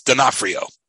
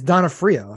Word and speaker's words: D'Onofrio: You say that it's D'Onofrio. Sorry. – I think it's because I thought a lot about this. I went on YouTube and like, there was like a pronunciation video just for Vincent D'Onofrio's name. – Okay. – So I think it's D'Onofrio.